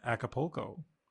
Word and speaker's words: Acapulco: Yeah, Acapulco.